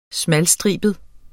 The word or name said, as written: smalstribet